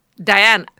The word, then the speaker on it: Diana: Her English name is Diana, from your mom. – Oh, wow. So oh, yeah, me, you told me that. – Yes. Diane is how we pronounce it.